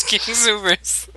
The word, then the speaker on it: King Soopers: It's funny because every time you say King Soopers, even though I know it's spelt like that, I always think of like Superman. It's King Supers.